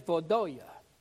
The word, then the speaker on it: Vaudois: One of the first groups, and it's hard for me to say this word, it's spelled V-A-U-D-O-I-S. They say it's Vodoya.